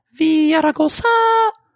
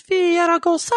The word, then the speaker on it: Villaraigosa: He prefers, by the way, his name is pronounced Villaraigosa. That's the way he likes it said. So, out of respect, please, Villaraigosa.